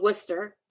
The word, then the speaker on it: Wooster: And it's called Worcester and it's spelled W-O-O-S-T-E-R, but it always wants to give me Worcester, Massachusetts Massachusetts.